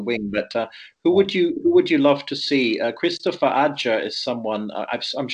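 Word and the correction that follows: Kristoffer Ajer: Scandinavian, you would say Christopher Ayer.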